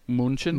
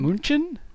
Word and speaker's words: München: How do you say Munich in German? Is it? – München. – München? – München. – München? München? That's like what you do with food.